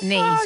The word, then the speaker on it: knee: I mean, you picked up knee straight away. – Oh, my Lord. – I'm a teacher. Oh, my God. I always teach the silent K.